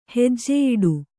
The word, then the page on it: ಹಜ್ಜೆಯಿಡು: hajjeyiḍu